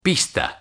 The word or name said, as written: pista